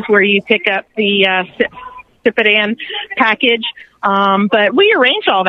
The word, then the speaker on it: Sipadan: to Saitapan, is that how you say it? How did you say that again? – Sipadan.